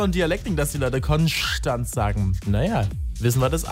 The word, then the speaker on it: Konstanz: Viele sagen immer Konstanz, es heißt aber Konstanz. Einfach nur Konstanz.